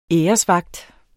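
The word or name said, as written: ÆRESVAGT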